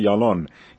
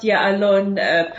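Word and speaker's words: Ya'alon: Well, first of all, you pronounced Yalon perfectly.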